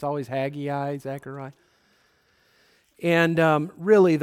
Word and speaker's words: Haggai: I've always pronounced it Haggai, but I think the correct pronunciation is Haggai.